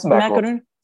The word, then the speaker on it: macaroon: Not macaron, not the president, a macaroon. How do you say that? As an American saying a French word? – It's macaroon.